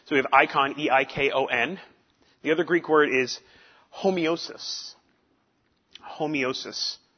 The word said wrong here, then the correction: homoiosis: H-O-M-O-I-O S-I-S, homoeosis, excuse me, I missed a syllable in there, homoeosis.